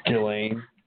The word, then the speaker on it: Ghislaine: Ghislaine? – Ghislaine.